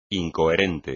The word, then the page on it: incoherente: espagnol